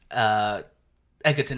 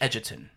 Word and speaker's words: Edgerton: People pronounce my name Egerton. It's actually Edgerton.